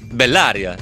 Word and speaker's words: Bellaria: Ci abbiamo speso, credo, almeno un paio di minuti prima della diretta nel dire dove va l'accento su Bellaria. Bellaria, giusto?